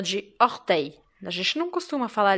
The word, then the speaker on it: orteil: E esse som se escreve essa palavra se escreve O-R-T-E-I-L. Então você percebe que o som EI do final da palavra se escreve E-I-L.